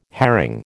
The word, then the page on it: herring: American English